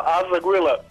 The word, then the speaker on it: Azagile: Bili su to Gnjev Anđela iz Bratunca, a sad se selimo u Novi Sad, kontaktiramo grupu koja se zove Azagila, ako sam dobro izgovorio. Dobro veče. – Dobro veče. – Jesam dobro izgovorio ili se tu drugačije izgovara? – Izgovara se zapravo Azagile.